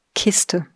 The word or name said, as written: Kiste